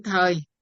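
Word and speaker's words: thời (時): 39, văn ngạn bác thiếu thời đều là chữ thời, hay là có khi người ta đọc là chữ thì nữa.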